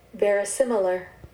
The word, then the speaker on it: verisimilar: See, I was pronouncing it wrong in my head when I first looked at it. I'm like saying very similar because that's how to me it was spelled Verisimilar.